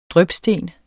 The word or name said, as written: DRYPSTENEN